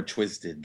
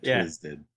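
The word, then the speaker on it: Twiztid: Yeah, Twisted. – Yeah, because ICP was already taken. They're like, we're painting our faces already. – Mm-hmm. You pronounce it like Twizzlers.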